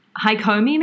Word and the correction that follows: Hakomi: Yeah, it was almost perfect. Somatica was perfect. It's Hakomi, but yeah, yes, okay.